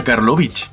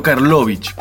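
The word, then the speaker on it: Carlovich: Y su apellido se pronuncia Karlovich, con acento en la I, y no Karlovich, como lo llamaron miles de veces.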